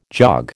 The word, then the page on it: jog: American English